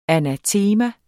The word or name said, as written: anatema